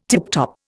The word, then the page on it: tiptop: Indian